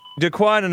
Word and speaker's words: Daquan: Daquan, and am I pronouncing your name right? – Yes, sir.